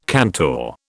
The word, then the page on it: cantor: British English